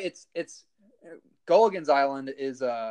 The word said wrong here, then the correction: Gilligan's Island: No, that's yeah, you're, you said Gulligan. It's Gilligan's Island, but you were saying in Gulligan.